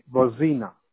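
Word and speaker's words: Bozena: It's B-O-Z-E-N-A, Bozina.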